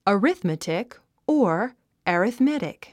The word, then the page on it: arithmetic: əríθmətik; アリスメティック